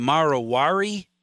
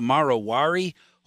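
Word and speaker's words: Marwari: The Marowari, is that how you pronounce it? The Marowari horse, known yes.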